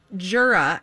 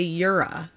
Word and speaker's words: Jura: Well, some people pronounce it Jura and others say Yura.